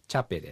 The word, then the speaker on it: チャペ: 猫のことはアイヌ語でチャペです。これがね、 CA って書いてチャチャとかね、こういうふうに発音するんです。ローマ字を見てね、ちょっとね、こう英語とかとだいぶ違うところなんですけどね、アイヌ語ではこれはチャっていう発音です。